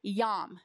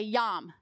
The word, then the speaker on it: yom: The Hebrew word for day that you see written up there is the word yom. Say yom. Which, when translated into English, yom has 50 different translations.